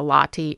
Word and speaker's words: Lahti: It's my name, Cynthia Lotti, L-A-H-T-I. – As opposed to coffee. – L-O-T-T-I or whatever.